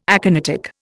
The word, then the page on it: aconitic: Indian